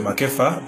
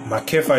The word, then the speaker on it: McEpha: My name is Victor McEpha. McEpha is the right pronunciation.